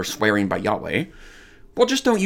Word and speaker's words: Yahweh: Typically, in English, we would say that that is pronounced probably Yahweh.